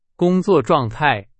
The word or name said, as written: 状态